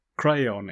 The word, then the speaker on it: crayon: A brown crown. Crayon, it's two syllables.